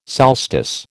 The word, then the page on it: solstice: American English